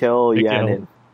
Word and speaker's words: Mikhail: it looks like Michael Janin, but I know Mikhail, it's pronounced Mikhail Janin.